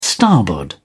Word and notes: starboard: British English

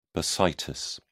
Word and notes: bursitis: British English

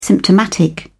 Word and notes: symptomatic: British English

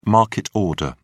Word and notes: market order: British English

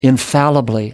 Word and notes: infallibly: American English

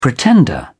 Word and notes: pretender: British English

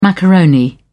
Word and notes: macaroni: British English

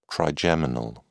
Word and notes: trigeminal: British English

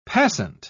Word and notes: passant: British English

